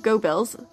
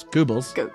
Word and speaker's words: Goebbels: Yeah, you're very close. Goebbels. Goebbels. – Like Goober? Goober? – Close. – But Goebbels? – Like Goebbels. – What? – Joseph Goebbels. – Joseph Goebbels. – You're fine. Your pronunciation was fine.